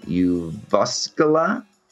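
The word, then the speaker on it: Jyväskylä: Sergei is professor at Uvskala. Did I say that right? – Perfect.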